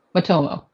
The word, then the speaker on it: Matomo: Am I saying that correctly? Momoto? – Matomo.